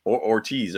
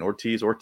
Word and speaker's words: Hortiz: Like, Ortiz. I keep saying Ortiz. – I know. – I one of those two. I think the H is silent, and that's just the way that I'm hearing it as.